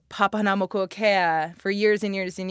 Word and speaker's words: Papahanaumokuakea: with them in this offshore postcard from Papahanaumokuakea.